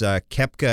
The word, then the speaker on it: Koepka: Yeah, so viewers, they saw Brooks Koepka, I think it's pronounced.